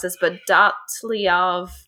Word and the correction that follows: Dyatlov: Dyatlov Pass, Pass incident. incident. – Yeah. J- yeah, Dyatlov Pass incident.